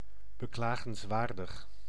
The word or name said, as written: pitiful